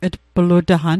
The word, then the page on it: tplutaqan: Mi'gmaq